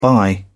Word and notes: buy: British English

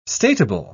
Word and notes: statable: British English

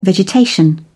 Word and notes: vegetation: British English

